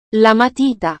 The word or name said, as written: la matita